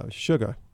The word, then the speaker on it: Sugah: All right, so one more upbeat one is called Sugar, like the way we pronounce it down south. – Mm-hmm. – S-U-G-A-H.